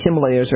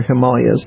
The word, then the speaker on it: Himalayas: Listen, I just yesterday, strangely enough, I saw a documentary called Maru, which is about a peak in the Himalayas, or Himalayas, depending on how you're going to pronounce it, which had not been climbed, has never been completely captured, as they say, conquered.